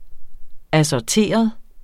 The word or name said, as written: ASSORTERET